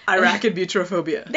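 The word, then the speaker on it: arachibutyrophobia: Irakibutyrophobia. That was close. – Irakibutyrophobia.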